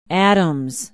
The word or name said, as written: Adams